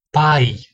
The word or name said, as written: pai